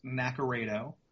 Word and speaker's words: Naccarato: Yeah, it's pronounced Naccarato. That's the Americanized pronunciation of it. It's Italian, so it's pronounced differently over in Europe. But yeah, my name's Mark Naccarato.